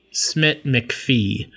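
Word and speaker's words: Smit-McPhee: Benedict Cumberbatch, Jesse Plemons, his wife Kirsten Dunst, Cody McPheesh. I don't know how to say his name. Hold on, I want to get this one right. Cody Smit McPhee he's getting a lot of Oscar buzz for actually a Best Supporting Actor nom.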